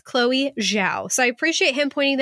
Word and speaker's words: Zhao: Chinese ZH is like English J, and Zhao rhymes with now, but with a J.